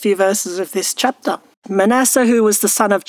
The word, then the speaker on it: Manasseh: Could be either Manasseh or Manasseh, depending on how you feel like pronouncing it. It's probably Manasseh.